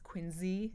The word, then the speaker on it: Quincy: And her portrait of Quincy, and I also have to just stop and acknowledge that I know it's pronounced Quincy. – Yeah. – I automatically pronounce it Quincy.